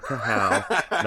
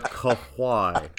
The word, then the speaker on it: Kawhi: I'm almost thinking of just stacking the Clippers and grabbing Kawhi and Paul George here. – God damn it. Are you just going to say Kawhi all year? – Kawhi? – Yes, not Kahow, not Kahoo, but Kawhi.